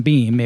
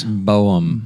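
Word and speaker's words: Böhm: It wasn't Beam. – It was Boehm.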